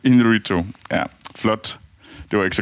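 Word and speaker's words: Iñárritu: Og for øvrigt, det, det er Inarito, du prøver at sige, når du siger banal.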